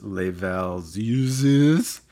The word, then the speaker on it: Les Valseuses: The film in question was 1974's Les Valseuses. – Great pronunciation, by the way. – Thanks. Yeah, I think I kind of nailed it.